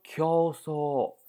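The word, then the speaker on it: 狂騒: 写成狂骚，哎，狂骚读成 q so 啊 q so，狂骚那就是疯狂的吵嚷呗，一个比一个程度高呗，就这样的啊。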